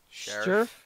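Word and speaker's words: Scherff: Sheriff.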